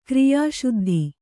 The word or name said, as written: ಕ್ರಿಯಾಸಿದ್ಧಿ